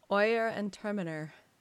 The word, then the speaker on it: Oyer and Terminer: Is that what we decided to go with? Oyer and Terminer? – Oyer and Terminer. – You've gone with that. – Well, that's how I've heard others pronounce it.